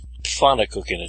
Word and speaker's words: Pfannkuchen: Oh, Pfannkuchen? Pfannkuchen.